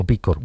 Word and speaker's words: B Corp: ¿B-Corp o B-Corp? – B-Corp. Bueno, es una sigla americana, anglosajona, y por eso es B-Corp.